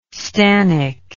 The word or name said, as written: stannic